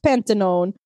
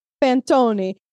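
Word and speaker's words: panettone: It was mostly because I needed to figure out how to pronounce it, because I was looking at it like pantanone, but it's pantoni.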